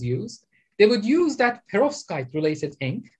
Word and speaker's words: perovskite: So it's perovskite. – P-E-R-O-V-S-K-I-T. Perovskite.